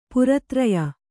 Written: ಪಾರತ್ರಯ

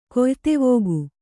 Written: ಕೆಯ್ದೀವಿಗೆ

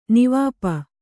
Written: ನಿವಾಪ

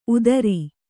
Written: ಉದಾರಿ

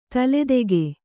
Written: ತಲೆದೂಕ

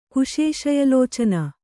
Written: ಕುಶೇಶಯಲೋಚನ

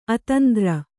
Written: ಅತಂದ್ರ

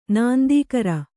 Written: ನಾಂದೀಕರ

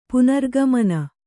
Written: ಪುನರಾಗಮನ

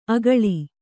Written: ಆಕಳಿ

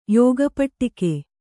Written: ಯೋಗಪಟ್ಟಿಕೆ

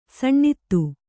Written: ಸುನೀತ

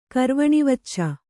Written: ಕರ್ವಣಿವಚ್ಚ

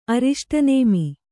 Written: ಅರಿಷ್ಟನೇಮಿ

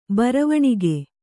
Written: ಬರವಣಿಗೆ

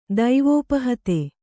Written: ದೈವೋಪಹತೆ

